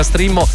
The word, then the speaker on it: Streammo: che si pronuncia Streamo ma si scrive Streammo.